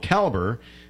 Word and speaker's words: Kaelber: They're called Calber, and it's spelled K-A-E-L-B-E-R, just so you know, if you're looking online or if you want to hook them up.